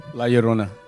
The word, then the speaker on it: La Llorona: But what is the correct pronunciation? – La Llorona.